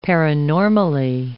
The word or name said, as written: paranormally